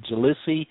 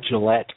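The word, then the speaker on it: Gliese: And boy, I'm glad that, that you tried to, or, you know, you probably did a better job than I at pronouncing the name of that planet, Jalesse 581d. You know, I would have mangled that just terribly. You know, I would have said like Gillette 581d or something like that.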